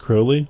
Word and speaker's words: Crowley: But Klosterman actually had this, not a chapter, but like a little 5-page section about Hitler and Aleister Crowley, which the main thing I learned that kind of blew my mind is that it's actually pronounced Crowley, which I didn't know that.